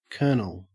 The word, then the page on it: kernel: Australian English